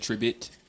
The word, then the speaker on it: Tribbett: Make sure you say that right. – I said Tribbett. Tribbett. Yeah, it is.